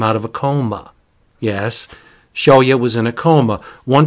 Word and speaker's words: Shuya: And she's very concerned with her older brother, Shuya. Or as Mia told me to pronounce it, Shoya. And that's how I will pronounce it, Shoya.